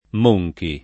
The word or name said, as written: Monchi